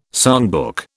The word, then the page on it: songbook: British English